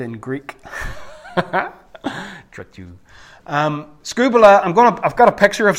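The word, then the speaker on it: skubala (σκύβαλα): It's spelt like the first one, but you pronounce it skubala. You're going to say this after me? 1, 2, 3. Skubala.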